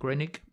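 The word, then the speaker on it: Groening: Das haben wir auch mit Matt, wie spricht man ihn aus? Granning? Granning? – Matt Grannick.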